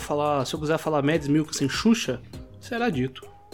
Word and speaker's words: Mads Mikkelsen: E só uma coisinha, em off você falou que tinha procurado a pronúncia e falado que o certo é Mads Mikkelsen e você falou Mads Mikkelsen umas 87 vezes nesse episódio, então...